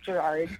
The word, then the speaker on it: Gerard: Yeah, a lot of silent vowels. But yes, it is Gerard.